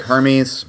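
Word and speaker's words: Hermès: Yeah, Stephen, Hermes is, it's spelled like Hermes, but said with an accent.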